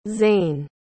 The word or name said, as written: Zane